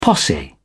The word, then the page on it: posse: British English